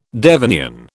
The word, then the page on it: Devonian: British English